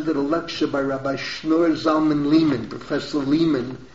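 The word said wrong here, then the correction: Leiman: or how does he pronounce it? Lyman, I should say.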